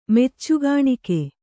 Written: ಮೆಚ್ಚುಗಾಣಿಕೆ

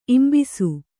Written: ಇಂಬಿಸು

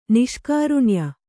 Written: ನಿಷ್ಕಾರುಣ್ಯ

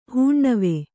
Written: ಹುಣ್ಣಿವೆ

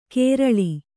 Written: ಕೇರಳಿ

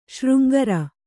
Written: ಶೃಂಗಾರಿ

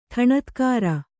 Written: ಢಣತ್ಕಾರ

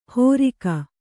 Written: ಹೋರಿಕ

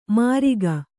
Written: ಮರಿಗ

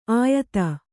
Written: ಆಯತ

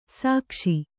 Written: ಸೇಕಿಸು

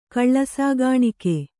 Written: ಕಳ್ಳಸಾಗಾಣಿಕೆ

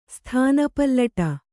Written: ಸ್ಥಾನಪಲ್ಲಟ

